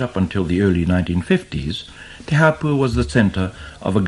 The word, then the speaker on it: Te Hāpua: Te Hāpua, once again, is a name that's been altered to make its pronunciation easier. Originally, it was Te Hōpua, the cove, the sheltering place of canoes.